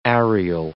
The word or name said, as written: areal